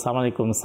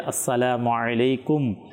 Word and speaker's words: আসসালামু আলাইকুম: এরপরে আরবীয় উচ্চারণ হয় কিন্তু সালামালাইকুম সালামালাইকুম, মানে শুদ্ধভাবে যে আসসালামু আলাইকুম শব্দ যে উচ্চারণ করা,